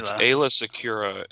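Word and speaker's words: Aayla Secura: The droid army has, Ayla Secura is the Jedi.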